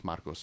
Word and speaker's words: Marcos: Marcos is how you pronounce it in Spanish. And my father brought that up to my mother, and she's like, "We're changing. It's not Carlos anymore. It's Marcos."